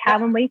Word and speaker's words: Calendly: I'm going to mispronounce it, but it's Calendly. Did I say that right? Calendly.